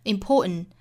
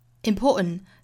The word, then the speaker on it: important: 我们注意这个 important 这个单词，我们听到原声发音是 important, important. 中间的这个 t 的发音是没有的。在语音学里面呢，这个叫做鼻腔爆破音。